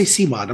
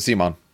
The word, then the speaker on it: CMON: C-M-O-N, isn't it? Come on. – I've always pronounced it Simon. – Okay. I thought I was having like a stroke for a second. – No. Same thing. Okay. I always say Simon.